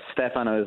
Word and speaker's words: Stefano: Stefano Pacifico, is it Stefano or Stefano? Let me pronounce it correctly. Welcome to the Lisa Wexler Show today. Hi. – Thank you. Hi, Lisa. Thanks for having me. Yes, Stefano is the right one.